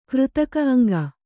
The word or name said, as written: ಕೃತಕ ಅಂಗ